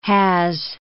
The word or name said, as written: has